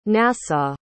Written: nassau

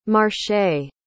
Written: marchet

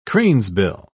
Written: cranesbill

